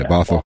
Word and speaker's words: Bothell: You moved into a home in Bothell. I'm pronouncing that correct, aren't I? – Yeah, Bothell.